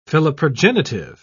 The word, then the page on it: philoprogenitive: British English